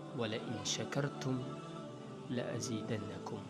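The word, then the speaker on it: Wala in shakartum la azidannakum (ولئن شكرتم لأزيدنكم): Which in Arabic is normally pronounced as, "Wala in shakartum la azidannakum."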